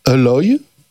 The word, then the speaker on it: Alloy: Qui s'appellera Alloy.